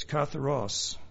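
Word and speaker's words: katharos (καθαρός): It's katharos, which originally, I know it sounds funny, but that's the way it's supposed to be pronounced apparently, which originally simply meant clean as opposed to soiled or dirty.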